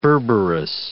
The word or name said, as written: berberis